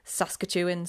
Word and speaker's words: Saskatchewan: Utah, Colorado, Saskatchewan, said it right that time, Canada, and Chihuahua in Mexico.